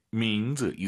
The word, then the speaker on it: 名字: 이름, 민즈, 즈, 쌍즈 아닙니다. – 名字, 민즈, 이성경성, 아하 생각하시면서 민즈 이렇게 발음하시고요.